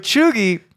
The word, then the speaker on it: cheugy: But choogy, the only thing I don't like about the word at this point is the way it's spelled. It's spelled C-H-E-U-G-Y.